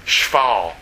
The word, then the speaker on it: shphal: In fact, the word lowest, it's a word when you say it, it sounds bad. Shfal. Let me spell it for you. Yeah, say this mouthful. Here's how you spell it. S-H-P-H-A-L. Not enough vowels there, are there?